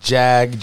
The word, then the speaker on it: Jag: I'm fucking with this cat named Jag, J-A-G. – Okay. – I think that's how you pronounce it, Jag.